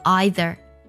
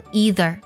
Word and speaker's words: either: 下面这个单词也是口语中以及写作中非常常见的，是两者之间的，那么英式的发音呢读作 either，一 I 组合呢发作 I 的音，而呢在美式的发音当中呢读作了 either。